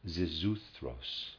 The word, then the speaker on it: Xisuthros: Zizuthros, I'll try to pronounce it, in the epic, spelled X-I-S-U-T-H-R-O-S.